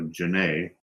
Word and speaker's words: Janae: Thanks, John, for an awesome show with legends who are all my inspirations, including yourself, from Janae Mister, and it's pronounced Renee with a J. So Janae, and Mister as in Mr. Hansen, like the title.